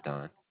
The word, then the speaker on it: Don: My name is Don. – Huh? – My name is Don. D-O-N.